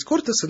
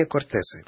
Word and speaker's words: кортесы: Иногда испанисты любят говорить кортесы, но все-таки я придерживаюсь традиционного ударения кортесы.